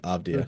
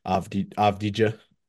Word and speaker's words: Avdija: pronunciation, I believe, Avdia.